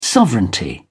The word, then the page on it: sovereignty: British English